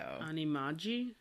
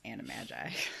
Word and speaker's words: animagi: I'm going with the hard G sound because it is short for like a shortened version of magician, right? So animagi, is that what you were going for? – Yeah.